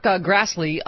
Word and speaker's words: Grassley: Senator Chuck Grassley, he who is dating sugary drinks. – Yeah, it rhymes with Astley.